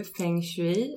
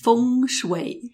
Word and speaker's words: Feng Shui: Och idag så ska vi prata om, jag säger ju Feng Shui, men jag har förstått att jag uttalar det hela fel. Men jag klarar inte att uttala det rätt. Feng Shui, nej. – Feng Shui.